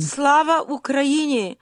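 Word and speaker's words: Slava Ukraini (Слава Україні): Slava Ukraini.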